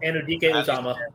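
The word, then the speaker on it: Anudike-Uzomah: Felix, and I'm going to butcher this. Anudike Uzuma. – Anudike Uzuma. – Thank you.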